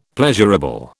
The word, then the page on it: pleasurable: British English